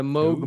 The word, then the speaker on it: Moog: Uh, and then I used the Moog uh, the Moog Ooh.